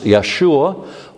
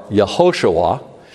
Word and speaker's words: Yeshua: So it's important we keep this in mind, because Jesus' name, which would be pronounced Yeshua or Yehoshua, is comprised of two Hebrew words.